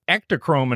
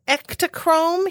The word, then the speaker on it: Ektachrome: And they said, tried to stick ectochrome in Herb's mouth.